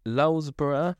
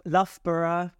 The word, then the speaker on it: Loughborough: The 67-year-old from Lulsborough is Loughborough.